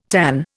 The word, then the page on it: tanh: Indian